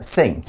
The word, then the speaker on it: Singh: Next, we see that the article says Ruth knows that something is wrong and forms a close friendship with Susie's school sweetheart, Ray Sai. – Uh, Singh. – Singh. No, yes, yeah, pronounced Singh.